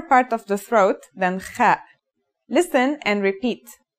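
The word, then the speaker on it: Kha (خ): The last letter we learn here is the Kha. This letter, like the Ha, has no English counterpart. Kha is a sound some people make when they laugh.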